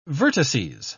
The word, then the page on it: vertices: British English